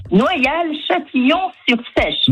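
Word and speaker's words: Noyal-Châtillon-sur-Seiche: Noyal-Châtillon-sur-Sèche.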